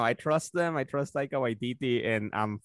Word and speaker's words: Taika Waititi: He just finished wrapping up Thor, Love and Thunder, and they posted a dope-ass pic of Chris Odinson, and how do you say that director's name? – It's Taika Waititi.